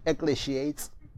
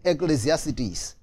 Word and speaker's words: Ecclesiastes: Okay, uh, some of you call it Ecclesiates. Okay, I'm, I'm pronouncing it the Myles Munroe way, Ecclesiastes.